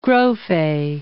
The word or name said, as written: Grofe